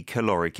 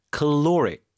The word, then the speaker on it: caloric: 근데 이제 여러분 들을 때 caloric 이게 calorie하고 너무 발음이 다르잖아요.